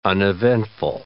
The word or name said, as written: uneventful